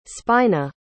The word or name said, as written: spina